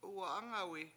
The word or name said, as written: Uwa7Angawi